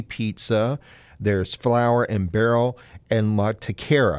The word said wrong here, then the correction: La Taqueria: Am I pronouncing that correct? – It's La Taqueria. – La Taqueria.